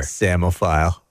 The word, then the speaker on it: psammophile: S-I-I? Shamophile? What? What other vowel could that be? – Okay, so it's a silent P at the beginning. – Oh, give me a break. Pussamophile. – P-S-A-M-M-O-P-H-I-L-E. – Oh, I wouldn't have got the double M's either. – Samophile.